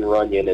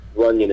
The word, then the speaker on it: Runion: So the name of the song is called Tattoos, and my name is Shane Runion, and Runion is spelled R-U-N-I-O-N. It's kind of like Union with an R in front.